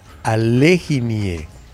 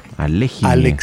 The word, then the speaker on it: Alekhine: Alexandra Alekhine. – Ah, miren. Alekhine.